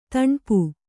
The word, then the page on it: ದಣಪೆ: daṇape